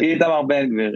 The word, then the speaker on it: Itamar Ben-Gvir: Itamar Ben-Gvir. Is that how you pronounce it? – Itamar Ben-Gvir. Yeah.